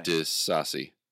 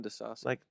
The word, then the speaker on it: Disasi: D-I-S-A-S-I. – Disasi. – I'm going Disasi. – Like Disasi. Like sort of saucy, but Disasi.